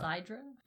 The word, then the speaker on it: clepsydra: And so the first clepsydra, I don't know if I'm pronouncing that right, that's just like the word that I found. – It's like hydra? – Yeah. – Clepsydra? – And so Clepsydra.